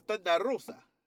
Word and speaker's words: Thunder Rosa: No, why you say her name like that? – Because that's how you pronounce her name, my friend. Her name is Thunder Rosa.